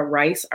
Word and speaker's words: Ryce: And you can see me on social media, on Facebook, it's Sundra Ryce, R-Y-C-E, Hardy.